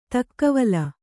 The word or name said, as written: ತಕ್ಕವಲ